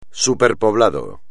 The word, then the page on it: superpoblado: espagnol